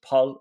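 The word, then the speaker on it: Paal: So it's Paul. It sounds better. But I think probably for the purposes of avoiding being insulting to a whole country, I'll just say Paul.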